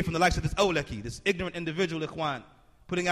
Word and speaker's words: Awlaki: This individual, Ikhwan, the Arabic word for brother, Awlaki, is how he's pronouncing it.